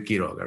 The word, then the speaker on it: Quiroga: No, this is always a debate because – my name is a Spanish name, right? It's Gabriel Quiroga, right?